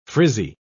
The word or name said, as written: frizzy